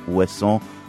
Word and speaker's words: Ouessant: Jan, this is your sixth studio album, recorded at your home studio on the island of Ouessant, which is off the coast of West Brittany in France. Am I pronouncing it right? Ouessant? – Yeah, perfect.